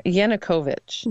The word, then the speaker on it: Yanukovych: Let me make sure I say his name right Yanukovych.